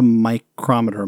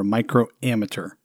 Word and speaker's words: microammeter: Not a micrometer, microammeter. – I mean, it's spelled microammeter.